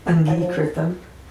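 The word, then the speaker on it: aṅgīkṛtam: Long E, I said that wrong. Angikritam.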